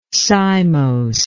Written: cymosely

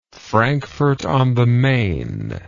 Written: Frankfort on the Main

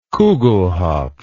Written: kugelhopf

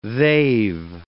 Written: they've

